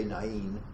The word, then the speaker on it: Nain: Now this little town, called, you want to say Nain, they would say Nain. The Greek diphthong A-I is pronounced I, like an aisle, you know, go down the aisle.